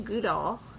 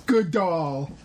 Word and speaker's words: Goodall: Say good. – Good. – All. – All. – Combine them. – Goodall. – Good doll. You're saying it like Gal Gadot.